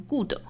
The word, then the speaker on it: good: 还有一个呢，就是 good 这个词 ,G-O-O-D, 很多同学把它读成 good, 应该注意是 good。